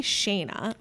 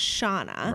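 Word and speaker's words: Shana: It's S-H-A-N-A, which is obviously Shayna, but right. It's pronounced Shauna.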